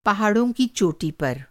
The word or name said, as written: चोटी